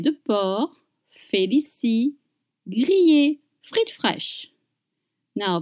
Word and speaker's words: porc: And you don't pronounce the C at the end of porc.